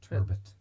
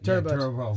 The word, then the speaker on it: turbot: Because, as you said, if you get a plaice or you get, like, a turbo. – Turbot. – Turbot.